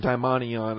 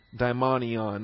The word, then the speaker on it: daimonion: All right, vocabulary you're going to want to study under subpoint B includes this term daimonion. That's number 1140. Daimonion, number 1140. D-A-I-M-O-N-I-O-N, daimonion. And there is a similar term, not here, but elsewhere in the New Testament, called a daimon.